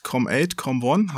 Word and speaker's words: Com8com1: Erst nochmal zu deinem Entwicklerstudio, das heißt Com8, Com1.